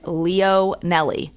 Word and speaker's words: Leonelli: No, people mispronounce my last name, and it's very phonetic, Leonelli. But they're always like, Leonelli? I'm like, I can't.